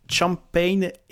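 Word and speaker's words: Champaign: Euh, la série s'appelle Champagne Hill. Ça s'écrit C-H-A-M-P-A-I-G-N. C'est comme champagne.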